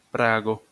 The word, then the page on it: Prago: esperanto